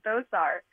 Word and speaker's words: Thosar: You had it right the second time. Thosar.